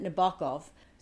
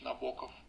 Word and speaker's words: Nabokov: There is a disagreement about how to pronounce his last name. I've heard it Nabokov. I've always said it Nabokov. So we went to the source of all sources, Wikipedia, to check. And now we're going to play it for you so you can hear that it is Nabokov.